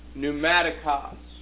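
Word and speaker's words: pneumatikos (πνευματικός): The word there is pneumatikos. Okay, and here's how it's spelled. I'm trying to remember my Greek. Okay, that's how it looks. Pneumatikos sounds like pneumatic drill.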